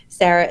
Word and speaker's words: Sarah: Sarah is spelled SARAH.